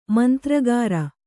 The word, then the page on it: ಮಂತ್ರಗಾರ: mantragāra